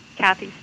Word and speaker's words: Kathy: Kathy, spelled with a K.